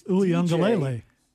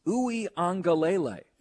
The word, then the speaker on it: Uiagalelei: I'm on this pronunciation kick right now because, I realized you and I were still doing DJ's name wrong on Clemson. It is Uiagalelei. – Uiagalelei. – Yeah, that's what I just said. – And that last part we weren't doing right.